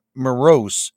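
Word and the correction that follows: Moross: Actually, it's Maras, I believe. – Maras, excuse me.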